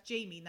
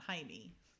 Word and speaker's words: Jamie: She's like, no, no, no, that's Jamie, not Jaime. Jamie.